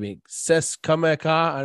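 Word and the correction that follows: C'est Comme Ça: Say Kamsa.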